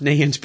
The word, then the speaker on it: Neyens: And his last name is pronounced Nayens, but it's spelled Neyens.